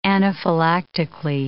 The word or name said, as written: anaphylactically